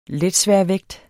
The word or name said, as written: letsværvægt